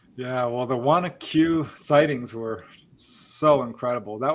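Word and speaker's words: Wanaque: Oh, which is, I pronounced it Wanak. It's Wanacue? – It's Wanacue. Yes, we hear all, no, you can't know unless you're in the area.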